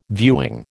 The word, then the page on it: viewing: American English